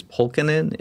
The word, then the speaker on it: Polkinen: My last name is Polkinen. It's 3 syllables. It's Polkkin Inn.